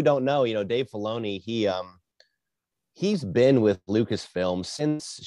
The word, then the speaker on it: Filoni: Am I pronouncing his name correctly? – Dave Filoni, yeah.